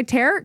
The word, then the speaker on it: tarot: Isn't it tarot? – I don't know. I'm Christian. – Because it sounds like you said carrot cards. – Tarot? Do you not pronounce the last T? – What do I know? – JP, do you pronounce last T? It's tarot cards.